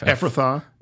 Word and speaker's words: Ephrathah: Yes, but you Bethlehem how do I say that? – F- Ephrathah. – Ephrathah.